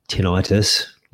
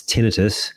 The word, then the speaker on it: tinnitus: Like the other day, it's not tinnitus, like a medical condition like you'd think, but it's tinnitus. That's the pronunciation.